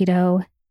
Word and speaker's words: Deux: I first tried their chocolate chip cookie dough. And since I've tried all the other flavors and I'm obsessed with their birthday cake flavor, they're so delicious. So Dough, it's spelled D-E-U-X.